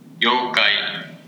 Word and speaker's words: yokai: Yokai. – Damn it. – All right, dude. Now you got to say it like that for the rest of your life. – Try it now. It's yokai. And you know what sucks is that I thought it was yokai, but then in the phonetic spelling, it's spelled YOU/KI.